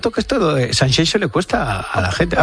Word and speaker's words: Sanxenxo: y te cuento que este fin de semana en Sanxenxo, no Sanxenxo, como dicen. – Qué bien lo pronuncias. – Sanxenxo, es como Shakira.